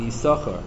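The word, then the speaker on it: Yissachar: So some Rishonim write, I think Rashi and Dibrayamim writes, that Yisachar is a Kriyoksiv, that it's spelled with 2 shins and you only pronounce 1 of the 2. Yisachar, you don't pronounce it Yisachar.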